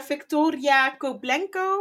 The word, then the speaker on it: Victoria Koblenko: Ehm, dames, what's up met eh, Victoria Koblenko, hoe heet ze? – Ja, je hebt het goed uh, uitgesproken.